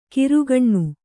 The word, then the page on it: ಕಿರುಗಣ್ಣು: kirugaṇṇu